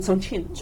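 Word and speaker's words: Chongqing (重庆): Chongqing. – Chongqing. – Maybe during Chiang Kai-shek, during, you know, whatever, the government now is in Taiwan. During that period, it's called Chongqing. Now I think it's called Chongqing.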